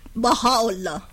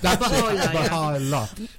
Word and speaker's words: Baha'u'llah: I can't pronounce his name, but it starts with Baha'u'llah. – Oh, that's it. Baha'u'llah.